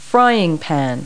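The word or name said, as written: frying pan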